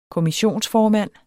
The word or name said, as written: kommissionsformand